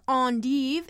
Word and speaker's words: endive: I'm talking lettuces, including ones that are bitter, and romaine, kale, watercress, endive, or endive, if you want to pronounce it the French way.